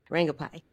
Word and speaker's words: Rangubphai: Rangubphai, yes.